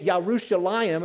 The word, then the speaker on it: Yerushalayim: So when the Jews say Yerushalayim, and that's how they say it when you're in their country, the last four letters of that Yerushalayim are A-Y-I-M, which is plural.